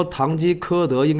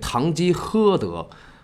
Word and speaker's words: 堂吉诃德: 上期呢，有一个网友纠正我一个读音，说堂吉诃德应该念堂吉诃德，还给我写了拼音。